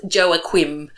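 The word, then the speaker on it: Joaquin: It's pronounced Joaquim.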